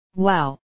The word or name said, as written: wow